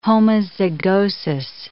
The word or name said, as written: homozygosis